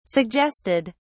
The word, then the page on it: suggested: British English